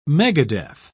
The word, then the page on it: megadeath: British English